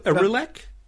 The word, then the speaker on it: Aurillac: Also, um, I'd like to talk about, and I hope I get the pronunciation right, but um, uh, is it Gerbert Gerbert of Aurelian? Have I got that? Auril- Aurillac? Yes, that's right.